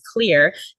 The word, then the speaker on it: Xlear: However, you can incorporate a nasal saline rinse that has xylitol in it a really good brand is Xlear. It's spelled with an X, X L E A R.